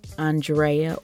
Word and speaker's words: Andrea: I am your burnt out host, Andrea, or Drea if you butcher the A-N portion of my name, which a lot of you will still do.